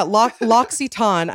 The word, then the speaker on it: L'Occitane: So yeah, L'Occitane, I think is how you say it. They actually taught me how to say it.